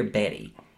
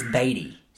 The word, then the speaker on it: Beatty: Is it Beatty or Betty? I think it's Beatty. – I think it is Warren Beatty.